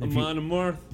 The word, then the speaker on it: Amon Amarth: Amon Amarth.